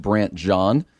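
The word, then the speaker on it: Brandt Jean: So this story continues to resonate of, I believe it's pronounced Brant John.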